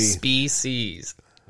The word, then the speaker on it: species: Species. Why can't I say it right? – I don't know. You sound like me, though. – Species. I got it. Yeah, there's no H in there.